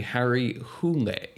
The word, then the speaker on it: Harry Hole: That's not how his name is pronounced. – Oh, really? – No, and for people who are familiar with Norway, I'm going to do my best to do it. Apparently, the Norway pronunciation would be Harry Hule.